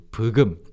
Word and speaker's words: 부금: And then they started just Koreanizing it to 부금. – 부금. – In those two Korean syllables. So 부금.